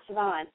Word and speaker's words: Siobhan: Siobhan. – Siobhan.